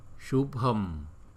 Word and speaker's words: Shubham: Rồi chữ BH là đọc là bh, bh. Shubham, Shubham, bờ, Shubham, Shubham, Shubham.